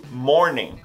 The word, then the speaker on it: morning: Não existe morning. Esse ing no final, você vai pronunciar como em. Em. Morning. Morning. E agora você vai falar os dois e vai ficar good morning.